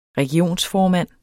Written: regionsformand

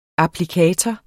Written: applikator

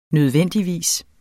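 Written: nødvendigvis

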